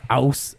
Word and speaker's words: Aass: It's pronounced aus. They have like they literally have it on the sign, but it's spelled A-A-S-S.